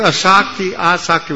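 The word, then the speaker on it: āsakti: The word asakti with a long A in the beginning, asakti literally means addiction.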